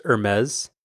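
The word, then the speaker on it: Hermès: Do you see that the how do you pronounce it? Hermes? Is that close? – It's pronounced Hermes, except I call it Hermes because I find the whole idea of high fashion dumb. But technically it's called Hermes.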